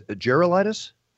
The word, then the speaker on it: Gerulaitis: Is that how you say his name? Is Gerulaitis? – Yes.